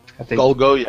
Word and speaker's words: Golgo: I think it's Golgo 13? – Golgo, yeah.